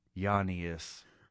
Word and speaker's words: Giannis: Giannis Antetokounmpo, I hope I said that right, the Giannis. – Greek freak. – Yeah, no G. – It's Giannis. – Giannis. – Giannis. – Yeah. – The soothing sounds of jazz flute played for you by Giannis Antetokounmpo.